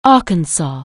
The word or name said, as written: Arkansas